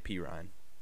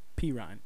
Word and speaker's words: Perrine: How do you say it? Is it Perrine? – Samaje Perrine. – Perrine.